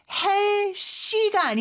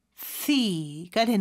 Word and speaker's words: healthy: 헬시가 아니죠. TH 발음해 주셔야 되는데 여기서는 무성음이에요. 그래서 스처럼 발음하는 것 같은데 발음을 내뿜는 소리로 해주시고요. 그래서 healthy가 되는 거죠.